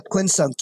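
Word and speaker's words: quincunx: And that's the quinsunk. Is that how you pronounce it? – Yeah, quinsunk, yes.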